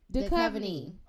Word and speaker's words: Duchovny: Duchovny.